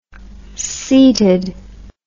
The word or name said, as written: seated